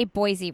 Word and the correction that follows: Boise: Boise with a C. – Boise.